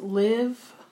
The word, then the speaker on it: Liv.e: And our song for the month is by, excuse me, I believe it's pronounced Live, LIV.E.